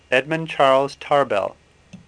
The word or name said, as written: Tarbell